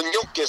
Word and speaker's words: gnocchi: Ja, men har jag helt fel när jag säger gnocchi? – Ja, det är åt rätt håll. Gnocchi heter det. Det är nj. Ljudet. Så gnocchi.